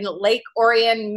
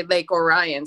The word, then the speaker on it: Lake Orion: Usually when you're not there, people say Lake Orion. So I'm very impressed that you said Lake Orion the way you did.